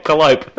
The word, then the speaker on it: Calliope: I was like, wait, can you sound it out for me? Can you spell it for me? Oh, okay, Calliope.